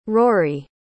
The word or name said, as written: rori